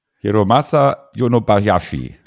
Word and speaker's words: Hiromasa Yonebayashi: Ähm, Regie Hiramasu Yonebayashi. Habe ich es richtig ausgesprochen? – Hiromasa Yonobayashi.